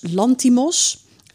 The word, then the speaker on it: Lanthimos: Dat is een film van, moet ik het even goed uitspreken, Jorgos Lantimos, een Griekse regisseur.